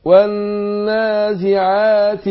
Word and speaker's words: والنازعات: على الرغم ان احنا بننطق ألفين هنا مش مكتوبين مش مرسومين، يعني بقول والنازعات غر والنازعة برضو بعد العين ألف، فأنا بنطق الحرفين دول الألفين دول ولكن هما مش مرسومين في المصحف.